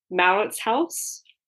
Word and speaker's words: Mauritshuis: and I'm going to butcher this, but maybe with your knowledge of Afrikaans, you can correct me, the Mauritshuis? – Yes, I guess. No, that's a good pronunciation.